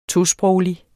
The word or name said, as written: tosproglig